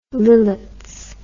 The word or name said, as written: lillets